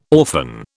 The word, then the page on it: orphan: British English